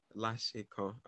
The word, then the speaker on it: Lashkan: We have Mr. Praise Lashkan.